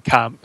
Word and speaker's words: Blomkamp: Is that how do you pronounce it? Blomkamp? – Blomkamp.